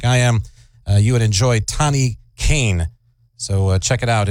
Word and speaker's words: Tane: I believe her name was Tani Cain, not Tane Cain, but it was phonetically looked that way. T-A-N-E, Tani Cain.